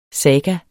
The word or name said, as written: saga